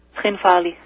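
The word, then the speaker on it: Tskhinvali: Skhinvali.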